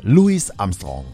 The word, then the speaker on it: Louis Armstrong: Wird englisch ausgesprochen mit S hinten aussprechen. Ne? Louis Armstrong.